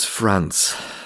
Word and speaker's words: France: Sein Name ist Paris, France. Also Franz wie der Vorname, aber englisch ausgesprochen klingt es wie France, Frankreich.